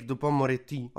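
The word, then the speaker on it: Dupond-Moretti: Eric Dupont-Moretti,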